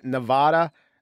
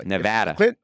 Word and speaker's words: Nevada: And by the way, if Clinton wins Nevada, sorry, I'll say it correctly. – Nevada.